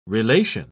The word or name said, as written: relation